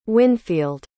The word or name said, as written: wynfield